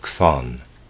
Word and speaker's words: Chthon: But Pherecydes also speaks of three principles of the world, of Kronos, of Zeus, and of Chthon. Chthon is spelled C-H-T-H-O-N.